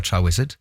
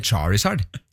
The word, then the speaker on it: Charizard: Jeg husker akkurat Charizard, skjønner du, fordi jeg ble rettet på når jeg sa Cha Wizard. Sånn, den heter Charizard.